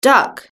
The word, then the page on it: duck: British English